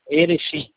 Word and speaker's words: Erechim: Erechim, Erechim, Erechim, como se fala?